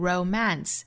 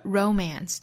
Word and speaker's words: romance: 我们来看 romance 这个词呢，其实重读可以在前，也可以在后，可以读成 romance，也可以读成 romance，romance 或者 romance 都是可以的。